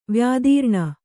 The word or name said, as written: ವ್ಯಾದೀರ್ಣ